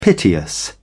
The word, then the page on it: piteous: British English